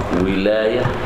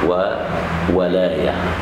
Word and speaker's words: ولاية: بعد كده الولاية في الزواج، الولاية تنطق ولاية وولاية بفتح الواو وكسرها، ومعناها في الاصطلاح الفقهي تنفيذ القول على الغير.